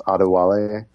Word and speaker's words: Adewale: By the way, the first name is pronounced Adewale.